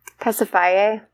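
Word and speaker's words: Pasiphaë: Is it Pacify? Pacify? – Pacifye. – Pacifye. Okay.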